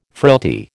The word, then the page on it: frailty: American English